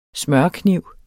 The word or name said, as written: smørekniv